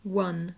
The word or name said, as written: won